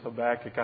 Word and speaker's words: Habakkuk: I believe the correct pronunciation is Habakkuk.